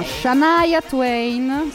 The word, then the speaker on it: Shania Twain: Siamo Shania Twain, giusto? – Sì, giusto. – Perfetto, la pronuncia perfetta.